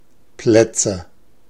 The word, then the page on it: Plätze: /ˈplɛtsə/